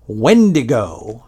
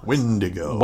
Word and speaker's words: Wendigo: So the first one is from my friend Tom Byrne, who tells me it's definitely Wendigo. – Wendigo. – Wendigo. So apparently we were pronouncing that incorrectly in the first episode.